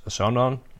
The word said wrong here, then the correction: Asghedom: I'm sorry I pronounced it. His first name is Ermias, and the last name is Asghedom.